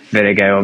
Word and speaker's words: Vingegaard: it was all about Vingegaard or Vingegaard, how you pronounce his name.